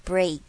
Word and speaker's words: break: 好，最后一句 to break down a wall，发现 BREAK 我们念 break 是什么母音，长还是短？没有错，是长母音哦，长母音的 A break，所以很容易犯的错会把它念成 break down a wall，这是我听得出来，要是请把它发好，发长母音 break down a wall。